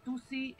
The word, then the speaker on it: tuci: Vamos a hablar de Tusi, eh, Tusi eh, se escribe con C de casa, por si lo quieren ir googleando en sus casas, si quieren ir eh, de qué se trata.